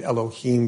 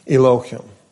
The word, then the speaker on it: Elohim: The correct pronunciation is actually, I believe, Elohim, but that is not a new word in church, right? Elohim or Elohim.